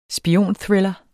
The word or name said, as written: spionthriller